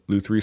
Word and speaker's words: luthiery: You went to luthiery school. Is that how you say it? – Luthiery.